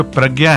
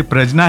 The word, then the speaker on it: प्रज्ञा: और जब हमने बात की थी, इनफैक्ट आपको मैं फिर से बताऊं, ये जो प्रज्ञा है, आप हमसे पूछ रही थी प्रजना है प्रज्ञा, स्पेलिंग तो PRAJN है क्योंकि जेन इज ज्ञा होता है अंग्रेजी में, इसलिए मैं प्रजना बोलना पड़ता है, अदरवाइज लोग गूगल करेंगे तो उनको मिलेगा नहीं।